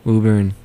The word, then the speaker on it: Woburn: Woburn. – Woburn. I was going to say Woburn. – There should be another O technically in the English language.